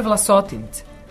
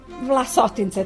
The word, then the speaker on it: Vlasotince: Ali ono su rekli da se kaže Vlasotince. – Vlasotince, tako bi trebalo da se akcentuje, da.